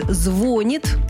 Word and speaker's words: звонит: Точные сроки перемен назвать сложно, но ученый считает вариант «звонит» ударением будущего.